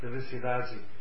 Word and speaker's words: felicidade: In Buenos, no, in Rio de Janeiro, when they say Felicidade, they pronounce D-A-D as with a like that Dadi, which Dadi, Ciudadi. So we have a useful name.